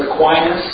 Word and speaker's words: Aquinas: His name is pronounced Aquinas. – Aquinas. – Long I.